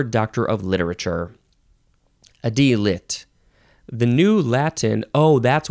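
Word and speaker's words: D.Litt: Now, I think you would call this, you'd say D-lit. Capital D, capital L, I-T-T, or you can only spell it with one T, which I feel like makes way more sense. D-lit.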